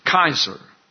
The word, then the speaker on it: Caesar: so it's pronounced Kaiser.